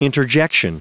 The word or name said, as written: interjection